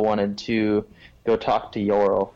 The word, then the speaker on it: Yorl: I want to go talk to that, I think I wrote down his name wrong, Yolorf. – Yorl, so it's Y-O-R-L, Yorl, like Yorl. – Oh, Yorl, like, gotcha. – Yeah, almost like Laurel.